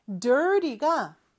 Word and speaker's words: dirty: 발음할 때는 저 T 발음을 좀 약하게 하죠. 그래서 water가 water가 되는 것처럼 저 T 발음은 real과 비슷하게 혀가 입천장에 살짝 닿아 떨어지는 그러한 표현입니다. Dirty, dirty 이렇게 발음하면 돼요.